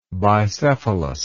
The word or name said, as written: bicephalous